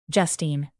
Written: justine

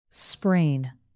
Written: sprain

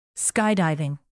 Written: skydiving

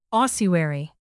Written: ossuary

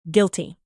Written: guilty

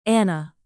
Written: anna

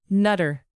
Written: nutter

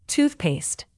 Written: toothpaste

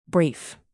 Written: brief

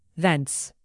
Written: thence

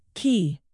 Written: key

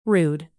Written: rude

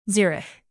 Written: zurich

